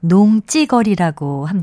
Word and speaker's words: 농지거리: 이 경우에는 쓸 때는 농지거리라고 쓰고요. 발음은 농지거리라고 합니다.